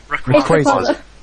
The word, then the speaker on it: Rayquaza: Ace Ryakuza. Am I saying that right? – Rayquaza.